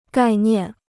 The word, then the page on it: 概念: gài niàn